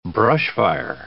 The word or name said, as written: brushfire